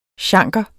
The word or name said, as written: chanker